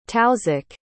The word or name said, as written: tausiq